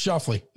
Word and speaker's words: Schauffele: Xander Schauffele. Shoffley? – No, it's Shoffley.